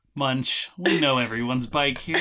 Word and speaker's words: Munsh: It says Munch, but it's spelled M-U-N-S-H.